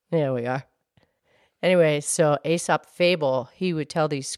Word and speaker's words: Aesop: Actually, I think his name is pronounced Aesop.